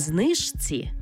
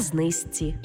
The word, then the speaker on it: знижці: Пишемо «жці», а вимовляємо «зці». Наприклад, не «подружці», а «подружці». Не «знижці», а «знизці».